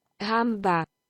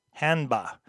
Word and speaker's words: Hanba: A shame is what Hanba translates to. Let's see if I'm even saying it right. – Hanba. – Hanba.